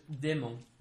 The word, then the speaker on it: démon: And if you want to say that in French, it's just démon. – Démon? – Démon. Pretty easy. Démon. It's just same spelling, but with an accent on the E.